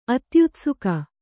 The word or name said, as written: ಅತ್ಯುತ್ಸುಕ